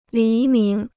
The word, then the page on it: 黎明: lí míng